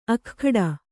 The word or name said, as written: ಅಗ್ಗಡ